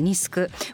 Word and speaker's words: NISC: はい、まあ、リスクこの番組でもねよく出てきますけれども改めまして NISC と書いてニスク